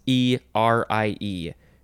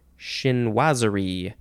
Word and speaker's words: chinoiserie: It is chinoiserie, chinoiserie.